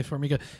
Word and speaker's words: Formiga: Am I saying his name right, Formiga? – Yeah, GCA Formiga.